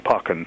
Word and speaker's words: Pauken: And the last name, ladies and gentlemen, is spelled P-A-U-K-E-N, Pawkin, Tom Pawkin.